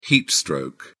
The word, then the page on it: heatstroke: British English